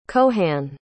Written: cohan

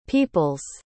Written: people's

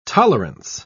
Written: tolerance

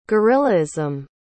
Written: guerillaism

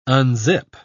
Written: unzip

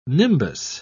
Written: nimbus

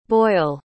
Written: Boyle

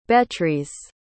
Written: Betrys

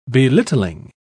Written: belittling

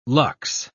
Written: lux

